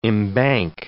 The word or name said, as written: embank